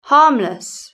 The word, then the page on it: harmless: British English